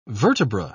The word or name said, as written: vertebra